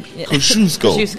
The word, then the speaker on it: Kosciuszko: That's what I called it out after I messed up and said Kazuki. – Because nobody can pronounce it. – No, nobody. Now you can. Kosciuszko. Kosciuszko. Kosciuszko Park is here. Cos Park for the locals.